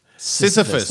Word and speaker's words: Sisyphus: We just dropped syphilis in the first minute. – Syphilis could be a person's name. – Oh, yeah. – Jack Syphilis. – Sisyphus. – Yeah. Sisyphus. That's I just said it wrong.